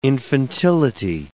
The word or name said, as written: infantility